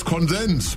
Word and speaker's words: Konsens: Ich spüre bei Ihnen allen eine Erschöpfung durch den Diskurs und eine tiefe Sehnsucht nach Konsens. – Ja, ich meine, das heißt Konsens. Betonung hinten.